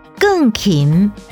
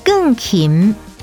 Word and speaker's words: 钢琴: 我妈妈是钢琴老师，钢琴台语念作更琴更琴，客语念作钢琴钢琴。 – 钢琴台语念作更琴更琴。